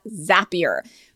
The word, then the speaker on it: Zapier: She's a hiring expert and currently a senior recruiter at Zapier. Rhymes with happier.